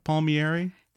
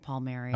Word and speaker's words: Palmieri: So it's Palmieri, it's not Palmieri? – It is. My family pronounces it Palmieri. – Okay. – Although the Italian way would be Palmieri. – Okay. – Mieri. It's kind of a mouthful.